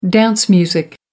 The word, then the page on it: dance music: American English